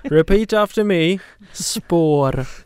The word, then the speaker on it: Spår: And Spår is spelled S-P-A-R with one of those cute little circles over the A.